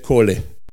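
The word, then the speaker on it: kole (קול): Dall'ebraico la parola voce è kol. Q di quadro, Otranto, V doppia, Livorno. Che si scrive così, ma si legge kole.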